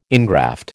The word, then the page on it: ingraft: American English